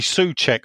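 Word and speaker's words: Souček: Uh, do you think we can get uh, Kuchek in? Sorry, Soucek.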